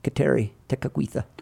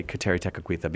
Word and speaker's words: Kateri Tekakwitha: And also we'll go to the tomb of Kateri Tekakwitha. Did I say that correctly? – You did. I'm really impressed with how you say Kateri Tekakwitha,